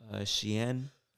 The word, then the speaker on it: Shein: Nah, it's called Shein.